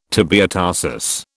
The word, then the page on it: tibiotarsus: British English